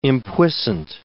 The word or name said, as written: impuissant